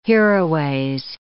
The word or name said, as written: hereaways